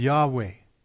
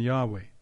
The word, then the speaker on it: Yahweh: and it is often written as Yahweh. The Old King James, in certain occurrences, it was written as Jehovah, which is probably a far less likely pronunciation than Yahweh.